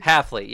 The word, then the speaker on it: Hafley: How do you say his last name? – I said Hafley. – Isn't it Hafley? – Hafley. Yeah, there's not a first L.